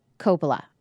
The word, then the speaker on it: Coppola: Am I saying her name right? Sofia Coppola. Coppola. Okay, I said it right the first time.